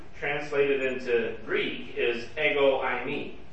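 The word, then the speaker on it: I me (εἰμί): You could just say I me, which in English letters we would spell it E-I-M-I, in case you're wondering.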